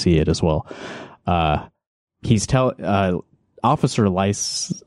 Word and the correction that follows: Lovick: Wait, did I say his name right? It's Lovick, Officer Lovick, Josh Lovick.